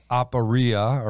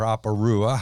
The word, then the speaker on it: aporia: Perplexity in the Greek is aporia or aporua, however you want to say it. – According to the computer, you said it right.